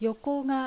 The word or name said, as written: Yokoga